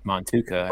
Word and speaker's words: Motueka: I'm glad you told me that it's pronounced Montuka. Oh. I've been calling it Motueka all this time. Yeah. Motueka hops.